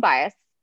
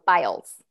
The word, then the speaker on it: Biles: Simone Biles.